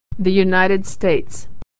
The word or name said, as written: the US